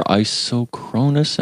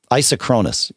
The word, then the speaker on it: isochronous: Oh, no, I see here isochronous and asynchronous is okay. – Yeah, they're two different things. Isochronous, yeah, is how it's supposed to be pronounced.